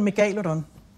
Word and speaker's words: megalodon: Jeg plejer at bruge de latinske udtaleregler. Mm-hmm. Så hedder den megalodon.